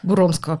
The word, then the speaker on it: Буромского: Буромского, правильно Буромск.